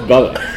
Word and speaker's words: Bubber: it's Bubber, not Bubba. – Well, it's spelled Bubber, but apparently he says it's pronounced Bubba, from what I understand.